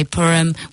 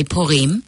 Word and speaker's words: Purim: So with an English pronunciation, we'd say Purim. With the Hebrew pronunciation, we'd say Purim.